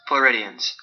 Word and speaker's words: Floridians: However, from the perspective of some Floridans, is that how you say it? Floridians. Sorry, Floridians.